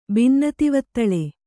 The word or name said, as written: ಬಿನ್ನತಿವತ್ತಳೆ